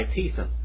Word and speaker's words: Thiessen: This book is written by Thiessen.